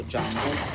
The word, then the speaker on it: Django: Jungle, la D è muta. – What's your name? – Django. The D is silent.